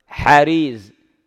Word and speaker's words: حريز: تصحيف ما يسلم من عنده، لأن في بعض الأحيان الكلمة هو ذكرها، أفرض تلفظ قال حريز، حريز بفتح الأول كسر الثاني وسكون إسكان الثالث مثلا وتحريك مثلا الرابع بما يقتضيه، عندما هم قال جرير ما فعل شيئا.